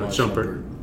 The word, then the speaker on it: Shumpert: Iman Shumpert. Shumpert? – Is that how you say it? – No, Shumpert. Yeah.